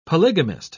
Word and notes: polygamist: British English

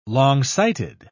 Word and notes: longsighted: British English

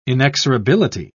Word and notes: inexorability: British English

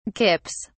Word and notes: kips: British English

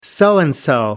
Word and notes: so-and-so: American English

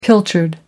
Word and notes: pilchard: American English

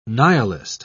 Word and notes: nihilist: British English

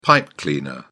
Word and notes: pipe cleaner: British English

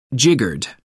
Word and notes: jiggered: American English

